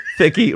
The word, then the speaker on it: Thicke: I've heard you all repeatedly pronouncing the E at the end, making Thicke rhyme with sticky. If you say, sorry. – That's it. Andy's gone. – Well, we had a good run. – If you're saying it this way for comedic effect, please disregard this note. If not, allow me to point out the E at the end of Thicke is silent.